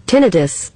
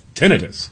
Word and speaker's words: tinnitus: Both are acceptable? – I guess. – Yeah, but the top American pronunciation is... – Tinnitus. – Tinnitus. – That is preferred in the medical and scientific community, also highly common in British English. So we're going to stick with tinnitus.